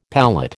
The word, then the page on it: palate: American English